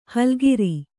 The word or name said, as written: ಹುಲ್ಲೆಕರು